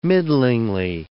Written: middlingly

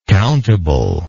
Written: countably

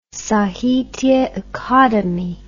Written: sahitya akademi

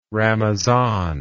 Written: ramazan

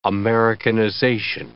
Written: Americanization

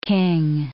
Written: king

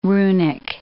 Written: runic

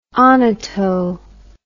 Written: onoto

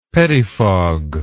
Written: pettifog

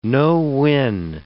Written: no-win

